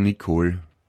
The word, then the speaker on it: Nicole: Das Kohl, das ist auch wieder der zweite Teil von Nicole. Nicole. – Ja, naja, das ist wieder bei uns. – Aber eine Nicole schreibt man auch zum Beispiel mit E am Schluss, obwohl man das eh nicht ausspricht.